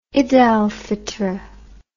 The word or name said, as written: id al fitr